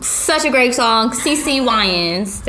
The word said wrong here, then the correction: Winans: Did I say that right? – Winans. – I'm sorry, great. Girl. She's really great. CC Winans, if you're listening, I got it right.